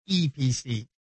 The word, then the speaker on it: Eee PC: I guess it's actually pronounced EPC, but it's three E's.